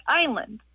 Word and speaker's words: island: Why is it island always spelled like island, and why do we always pronounce it like island? – Oh, what a good question. Why does island have an S in it, and why is it pronounced with a long I?